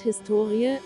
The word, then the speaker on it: Historie: Das Wort Historie, HS2IE, die Betonung liegt auf dem O bezeichnet bis in das 18.